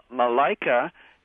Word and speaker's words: Malaika: You know, Malaika is, I assume that's M-A-L-A-I-K-A? It's pronounced that way? – Correct.